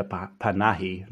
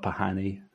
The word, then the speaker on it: Panahi: Rita, I mispronounced her second name. It's Rita Panahi, not Pahani.